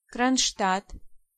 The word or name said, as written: Kronstadt